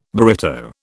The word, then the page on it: burrito: British English